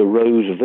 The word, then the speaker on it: Wroe: And I'm not even sure if it's available online, but there was a drama in 93 called Mr. Rose Virgins, with a silent W-R-O-E, Mr. Rose Virgins, set in the north of England, based around a rather strange religious sect.